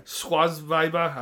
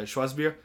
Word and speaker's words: Schwarzbier: Schwarzweiber. I don't know how to say it. I'm sorry. Schwarzbier.